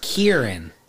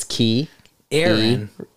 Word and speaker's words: Kieran: Kieran. – Yeah, but Kieran. Has it with three syllables. He's saying Kieran. – Because when you look at it, it's Key. – Aaron. – Key Aaron. – Kieran.